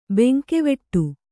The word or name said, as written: ಭಂಗವಡೆ